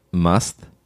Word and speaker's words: Musth: Do you know about Musth? – What? – I think it's pronounced Musth, but it's M-U-S-T-H.